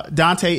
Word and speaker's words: Dontaye: So Dante, it's spelled D-O-N-T-A-Y-E.